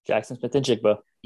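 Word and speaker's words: Jaxon Smith-Njigba: Because earlier in that game, Jackson Smith and Jigba, did I say that right? – Jackson Smith and Jigba.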